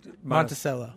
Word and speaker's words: Monticello: Monticello? – Yeah, we pronounce it wrong there. Monticello.